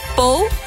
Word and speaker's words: Pau: Pau.